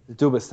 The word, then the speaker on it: Dubas: Dubas, that's it.